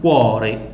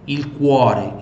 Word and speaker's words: cuore: São aquelas que têm, mais uma vez aqui, o coração, il cuore, cuore, tá? Pronuncia bem o U e o U aqui, ó. Il cuore, il cuore.